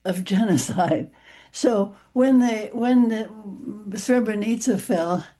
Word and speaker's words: Srebrenica: One was the Srebrenica massacre. – Srebrenica. – Thank you. It's been so long, I've forgotten how to pronounce it.